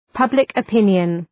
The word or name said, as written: public opinion